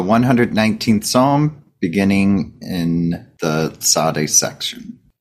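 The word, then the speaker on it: Tsade: Yeah, so we're looking at Psalm 119, verses 137 through 144, which is the section Zadeh, if I'm... Is that how to say it? – Yeah. – Zadeh, like Zadeh. – That? Okay. Kind of a TS or TZ sound.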